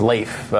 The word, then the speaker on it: Leif: I believe it's Leif. It rhymes with rake, if you remember.